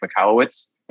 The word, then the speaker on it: Michalowicz: Uh, today we have on very special guest Mike Michalowicz, and I pronounced that right, correct? You nailed it.